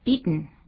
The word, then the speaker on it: beaten: 最后那个念的时候变成 beaten，应该怎么念呢？ – beaten beaten 用等音鼻音出现。